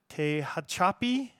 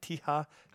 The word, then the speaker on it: Tehachapi: They're from Tehachapi. How do you say that? Tehachapi. Tehachapi. All one word. – I think Tehachapi.